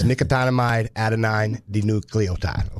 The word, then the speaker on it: nicotinamide adenine dinucleotide: NAD, and I'm going to try to say it for you, is nicotinamide adenine dinucleotide.